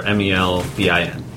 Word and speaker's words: Melbourne: Melbourne's? – Melbourne. No, it's pronounced Melbourne. – Melbourne. Yeah. – Melbourne. – There's an R in it, therefore it's Melbourne.